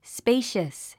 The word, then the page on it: spacious: spéiʃəs; スペイシャス